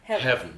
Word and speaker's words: heaven: Give it a second syllable. Exactly. – Yeah. – He wants it one syllable. Heaven.